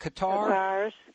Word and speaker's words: Cathars: Cathars, yes.